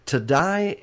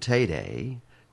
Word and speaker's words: Taddei: Tadai is how I've been pronouncing his name. But I listened to someone say his name Tayday, T-A-D-D-E-I. – Like today.